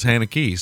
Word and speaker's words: Haneke: No, but, I've always heard every interview I've ever heard with Michael Haneke has been pronounced Haneke, so I guess all of us Americans do say it that way.